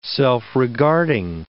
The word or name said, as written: self-regarding